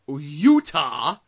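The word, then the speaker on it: Yuta: Red Eagle, Jeff Cannonball, Tara Calloway, Eric Royal, Daniel Maccabe, Shaza McKenzie, Sage Phillips, Utah, not the state, but it's Y-U-T-A, and that's the way I pronounce it.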